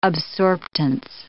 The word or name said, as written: absorptance